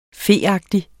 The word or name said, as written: feagtig